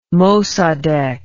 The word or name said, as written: Mossadegh